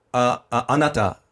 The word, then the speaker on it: anata: So you're supposed to say anata is like, excuse me, sir, or madam, which is nai. – How do you say it? – Anata.